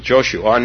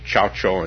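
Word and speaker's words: Zhaozhou: Well, Joshu, now I'm using, now I'm falling back on the Japanese pronunciation, Joshu. So I may go between Chaucho and, I'm trying to do the Chinese pronunciation, which is actually correct, but for 20 years I only knew the Japanese, so, okay.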